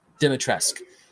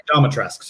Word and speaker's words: Dimitrescu: When it came out, I saw how do you say her name? – Dimitrescu. Domitresk, sorry. – Domitresk, oh, that's even better. – I think that's right.